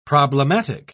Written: problematic

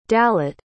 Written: dalit

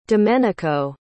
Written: domenico

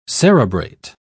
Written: cerebrate

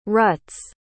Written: rut's

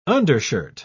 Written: undershirt

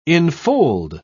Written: infold

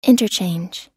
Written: interchange

